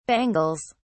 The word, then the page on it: bangles: British English